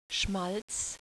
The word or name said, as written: schmalz